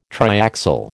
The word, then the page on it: triaxial: American English